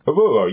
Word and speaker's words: Oruro: One of the places that really interests me was a place called Oruro. Is that the correct pronunciation? – Oruro, yes. Yes, it is and i w- I'm fascinated by these sort of British outposts around the world.